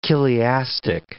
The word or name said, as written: chiliastic